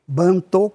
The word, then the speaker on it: Bantu: Bantô com O no final, e Bantu, que as pessoas naturalmente pronunciam como Bantu.